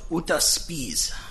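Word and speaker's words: Jutta Spies: The next case is Jetta Spies versus Carolyn Colvin. – May it please the court, good morning. On behalf of the plaintiff, it's pronounced Uta Spies. She's a German immigrant.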